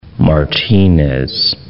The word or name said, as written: Martinez